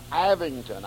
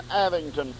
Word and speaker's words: Avignon: And I presume you pronounce this Avignon. I'll spell it, A-V-I-N-G-N-O-N.